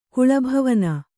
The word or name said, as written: ಕುಳಭವನ